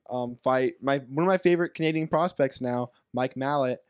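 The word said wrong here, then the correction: Malott: I'll say his name correctly, Mike Malote.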